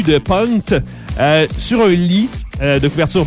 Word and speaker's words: Punkt: Si on peut décrire la pochette, si vous ne l'avez pas vue encore à la maison, cherchez Pierre Lapointe, Punkt, P-U-N-K-T, qui apparemment, faut le prononcer Punt, parce que c'est de l'allemand et ça voudrait dire point d'exclamation.